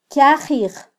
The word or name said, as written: kyajij